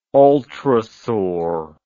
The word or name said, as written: ultrasaur